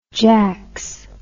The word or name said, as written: jacks